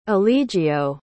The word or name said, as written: eligio